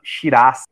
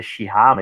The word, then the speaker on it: Shiraz: No caso dos vinhos, é curioso citar o caso da uva Shiraz, muita gente pronuncia Shirá, mas enfim, é um símbolo nacional da Austrália, mas tem origem francesa.